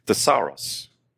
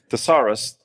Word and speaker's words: thesauros: And that's the Greek word thesauros. Wait, that sounds like the first word. It does. It also sounds like the word thesaurus, right?